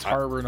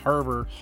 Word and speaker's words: Harbor: It's Harbor, not Arbor, by the way. – What? – Harbor. – Harbor? – Not Arbor. Sounds, the H is silent in my world, okay? – Uh-huh, okay.